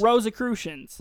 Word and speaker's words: Rosicrucians: Is that not how you say it? Rosicrucians? – Rosicrucians. – Rosicrucians. – Yes. – Excuse me. Rosicrucians.